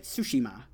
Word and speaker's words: Tsushima: The other thing that we also got a closer look at was Ghost of Tsushima, or Tsushima. Am I saying that right? Tsushima. Is that perfect? – Okay.